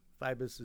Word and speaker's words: Feibus: Feibus is spelled just the way it sounds.